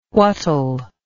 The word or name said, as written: whatll